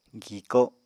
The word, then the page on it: giko: Apatani